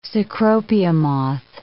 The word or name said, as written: cecropia moth